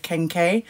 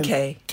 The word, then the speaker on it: kenkey: So we have another dish called kenke.